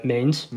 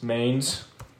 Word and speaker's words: Mainz: Then we have Red Bull Leipzig versus, I don't even know how to pronounce it. – RB Leipzig versus Mainz. – Mainz. On the money line. – It's in, yeah, you got it, Homebrew.